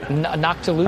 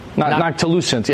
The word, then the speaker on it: noctilucent: Isn't the, how do you say that, non-ticulant cloud, right? – Noctilucent? – Non-ticulant cloud? Noctilucent. Yeah, yeah. I've mispronounced it like 1,000 times.